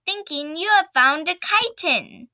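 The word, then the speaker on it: chiton: It's a chitin, pronounced chitin.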